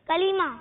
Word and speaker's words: کلمہ: کلمہ. – ہاں کلمہ کھینچیں گے بھی نہیں. کلمہ ٹھیک ہے. کیا ہے؟ – کلمہ. – ہاں کلمہ درست نہیں ہے بلکہ درست کیا ہے تلفظ؟ – کلمہ.